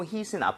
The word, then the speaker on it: heat: 그리고 발음 주의하셔야 되는 게 heat은 이렇게 치다 할때 H-I-T죠. hit. – 아, 그건 다르죠? – 다릅니다. H-E-A-T는 중간에 모음이 두개 끼면서 입모양이 좀 벌려져야 돼요.